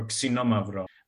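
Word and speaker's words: Xinomavro: And the main grape that we talk about and that is grown in this area is called Xenomavro or Xenomavro with the proper Greek pronunciation.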